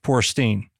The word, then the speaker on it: Feuerstein: Feuerstein.